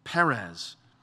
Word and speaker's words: Perez: And in Hebrew, this word breach it sounds like the word Perez or Paraz. Therefore, his name was called Perez.